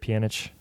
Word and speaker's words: Pjanic: Is it Pjanic? – Yeah, correct.